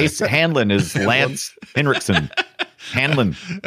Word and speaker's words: Hanlon: A lot of people say his name wrong, and I don't know why. It's something. – Hanlon? – It's burned into my head. It's Hanlon.